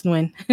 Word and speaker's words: Nguyen: I believe it's in Portland, Oregon, and it is a place where Jenny Nguyen, is that how you say it? Do you know? – I believe it's Nguyen. – Thank you. Nguyen.